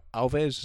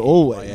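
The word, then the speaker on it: Alvvays: We got Alves. Is that how you pronounce it? Alves? How do you? I never know how you pronounce it. A-L-V-V. – Oh, always. It's just always. I'm pretty sure.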